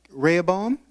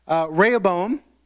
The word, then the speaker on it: Rehoboam: Rehoboam, thank you for the interpretation of tongues. I appreciate it. Okay? Rehoboam.